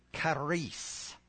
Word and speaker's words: charis: The C-H is pronounced K, charis.